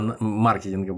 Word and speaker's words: маркетинга: которая изначально работает по методу этого сетевого маркетинга. А маркетинга будем э, повторять это теперь с нормальным ударением.